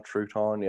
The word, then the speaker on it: Trouton: We, like, my last name is Trouton, but they spell, they say it Trouton. – Yeah. – Like a crouton, Trouton.